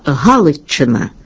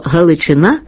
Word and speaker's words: Галичина: Виняток з цього правила становить лише слово «Галичина». Від прикметника «Галицький» за нашим правилом мала б бути форма «Галичина» з наголосом на першому складі і двома «ч» на письмі. А насправді маємо іменник з одним «ч» і наголосом на закінченні. Слово «Галичина» закріпилося в літературній мові згідно з місцевою традицією.